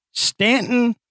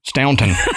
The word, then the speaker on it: Staunton: Now, I've, I've, I've heard it pronounced two ways Stanton or Staunton. What? Uh, anyway, Staunton. Staunton. Staunton. Okay. but it's I think it's it's uh Staunton, Virginia.